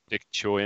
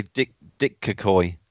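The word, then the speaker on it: Daichi: Dick Choi, isn't it? – Dick Choi, is it? – Yeah, Kagashow, Dick Choi, yeah. – Dick Choi. I was going to say Dick Kakoi.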